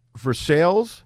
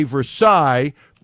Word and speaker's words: Versailles: Versailles, you know how I pronounced it? Versailles. – Exactly. – And I said, where is Versailles? And somebody in the newsroom said, hey, and corrected me.